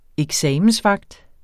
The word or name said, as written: eksamensvagt